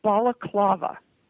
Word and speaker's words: balaclava: Opus Balaclava. – Balaclava, okay. – Yes. Not to be confused with baklava, which is a pastry.